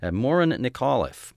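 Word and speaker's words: Muireann Nic Amhlaoibh: And the singer with the group, her name, he coached me on the pronunciation. It doesn't look anything like this unless you're Irish and you might figure it out. Morin Nicolliffe, and she is in this tune from their disc, which is called The Road Less Traveled.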